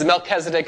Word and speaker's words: Melchizedek: Yeah, it's Melchizedek. It's a lot of ways you pronounce the guy's name. Mel. Whatever. Terrible spelling.